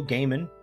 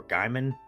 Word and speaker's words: Gaiman: Now, this is both a book and now a TV series written by Neil Gaiman, or Gaiman. I, think he pronounces it Gaiman, but I thought for the longest time it was Gaiman.